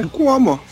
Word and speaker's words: Cuomo: Mas então, a formação da banda é o Rivers Cuomo, Kumo, não sei como é que se pronuncia esse Cuomo. É Cuomo.